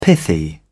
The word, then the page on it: pithy: British English